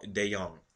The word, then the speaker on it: DeJong: It's Paul DeJong. The J is silent, or like a Y, young.